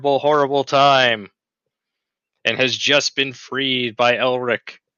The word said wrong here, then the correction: Erlich: Erlich. I'm going to get the pronunciation right someday. It'll be session 100, but we'll be there. – Erlich.